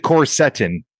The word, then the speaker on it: quercetin: Okay. Pronounce it quercetin. Did I get that right? – Quercetin. – Quercetin. – Yeah, quercetin.